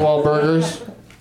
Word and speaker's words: Wahlburgers: Are you saying you just go into Wahlberg's and drink a shake? – Oh, shit. I should start doing that. – I mean Wahlburgers.